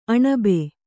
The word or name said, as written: ಅಣಬೆ